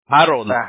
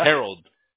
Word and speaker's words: Herald: mencionando como español, Harold, pero en inglés es G, como que nomás pusieras una G y luego Road. Ajá. Herald.